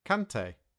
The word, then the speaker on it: Kante: And for Kante, the player, there's an A sound, so we say Kante.